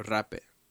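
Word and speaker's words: rapé: Another form is called rape. It's spelled like rape, but it has the accent on the E. – Obviously. – Yeah, and it's jape or rape, you know.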